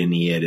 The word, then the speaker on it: Ennead: is the Ennead? Am I pronouncing that? – Ennead, yeah.